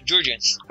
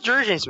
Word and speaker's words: Jurgens: Jurgens. – Ah, em inglês é é Jurgens mesmo. – Não é Jurgen? – É? Não, Jurgens. Jurgens.